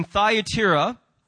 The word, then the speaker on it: Thyatira: In Thyatira, somebody told me to pronounce it that way because the other way sounded kind of weird. So in Thyatira, they tolerated a false teacher who was leading many people astray.